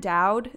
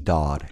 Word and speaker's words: Dowd: Anne Dowd is probably the biggest. – It's pronounced Dodd.